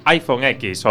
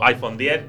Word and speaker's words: iPhone X: su iPhone X o iPhone 10, que tendría que ser en principio esa eh, pronunciación correcta.